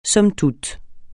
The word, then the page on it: somme toute: francais